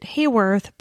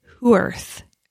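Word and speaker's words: Haworth: So where Claudia Lawrence is from in the UK, I pronounced it Hayworth, but it's Hooworth.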